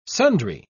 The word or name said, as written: sundry